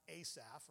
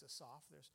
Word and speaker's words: Asaph: There's no long A in the Hebrew language, so it's not Asaph, right? It's Asaph. So we have Asaph.